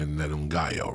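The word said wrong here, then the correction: Ngannou: right? Like, when he fought Stiopic. – Ungano, when he fought my man, Immigrant Mentality, like a motherfucker.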